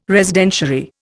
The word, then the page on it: Residentiary: Indian